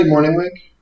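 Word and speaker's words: Mornhinweg: I think I'm saying that right. Mortenweg? – Marty Mortenweg. – Mortenweg. Okay. Yeah, that sounds right. Yeah.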